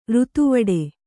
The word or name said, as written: ಋತುವಡೆ